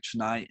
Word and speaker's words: Chennai: It's set in your native, in your home of Chennai. Am I pronouncing that right? Chennai, India? – Yeah.